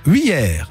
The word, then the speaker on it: Wiers: Dites-moi, comment ça se prononce l'endroit où vous êtes ? C'est Wiers ? – Non, Wiers. Wiers, d'accord, Wiers. W-I-E-R-S.